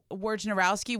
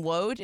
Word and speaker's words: Wojnarowski: And then Adrian Wojnarowski, we call him Wode. It's Wojnarowski. – It's Wojnarowski, yeah. – Wojnarowski, because it's spelled with a J.